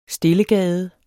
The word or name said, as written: STILLEGADES